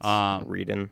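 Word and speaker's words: Wreden: The Stanley Parable developer, Davey Redren? – I think it's Reedon. – Reedon. Probably don't pronounce the W.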